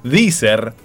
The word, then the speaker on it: Deezer: yo uso otra que se llama Deezer, que se escribe D-E-E-Z-E-R,